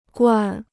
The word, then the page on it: 惯: guàn